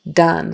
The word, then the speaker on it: done: The word done, done, like I'm all done. Even though it's spelled D-O-N-E, it's got to be a short U. Done, done, done.